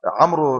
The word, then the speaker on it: عمرو: عمرو ابن مش عارف ايه، مثلا عمرو اسمه عمرو احمد بتاع يقول لك عمرو احمد محمد عمرو، ينطق الواو. غلط، الواو دي مكتوبه بس تكتب ولا تنطق، والالف اللي موجوده في كلمه مئة تكتب ولا تنطق.